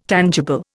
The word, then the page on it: tangible: Indian